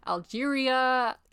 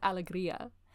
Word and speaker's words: Alegria: The whole time that we were going up, we were like, Algeria. – Yeah. – Alegria. It's Alegria.